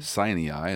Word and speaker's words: Sinai: The Jews revere Moses because Moses was given the law face to face from Jesus on the top of Mount Sinai, or Sinai, as people are pronouncing it today.